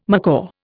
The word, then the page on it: macaw: Indian